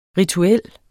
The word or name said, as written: rituel